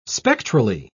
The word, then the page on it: spectrally: British English